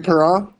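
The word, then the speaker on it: Parent: Serbe also set a NHL record for the most minutes in a season, breaking the mark of 4,314 played by Bernie Pernay. How do you say his last name? Bernie Pernay? – Bernie Perron? – Perron. I'm not good at that, at those last names.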